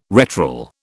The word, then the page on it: retral: British English